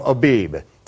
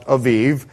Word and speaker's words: Aviv: As it is written in the King James, in the English version, it says the month of Abib. In Hebrew, it would be pronounced Aviv.